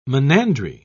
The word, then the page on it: monandry: British English